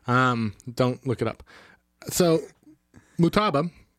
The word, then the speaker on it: Motaba: You're putting emphasis on the wrong vowel. Motaba. – Motaba. – You know what? No, it sounds like you're asking for more taba.